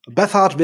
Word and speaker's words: Bethard: Oder Bizard oder wie auch immer. – Bethard wäre schon richtig.